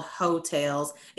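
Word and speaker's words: Heaux Tales: It's called Hotels. It's spelled H-E-A-U-X, Tales.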